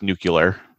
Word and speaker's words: nuclear: Yeah, and I can't wait to ask him about nuclear submarines. – It's pronounced nuclear, actually.